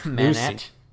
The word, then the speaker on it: Manette: Lucy Manette. Manetti. It's spelled two different ways in these credits. – It's Manet.